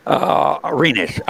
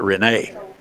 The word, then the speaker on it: Rennes: Yeah, Reams. Renes, R-E-N-N-E-S. – Okay. – We called it Renee. – Yeah, I got you. – Yeah, that's how we pronounced it.